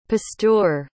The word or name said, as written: pasteur